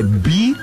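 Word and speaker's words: BYD: É que nem quando o cara que vende carro, ele vende carro e ele fala BID. Não é B-I-D, é B-Y-D. – Ah, eu adoro quando tu fala inglês. – Porque o Y é a pronúncia, é o nome, é como se fala a letra Y em inglês. – Belo Horizonte. – Né? – Exatamente. – B-Y-D. O cara que vende B-Y-D e fala saber.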